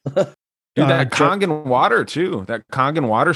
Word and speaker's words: Kangen: It's Kangen. I think it's spelled K-A-N-G-A-N, Kangen, and it's Japanese,